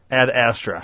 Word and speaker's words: Ad Astra: Ed Strada? – Oh, Ad Astra? – Ed, is that how you say it? – Ad Astra.